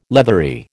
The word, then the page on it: leathery: American English